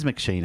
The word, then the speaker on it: McShane: Usually butchered. Yeah, it is McShane, actually.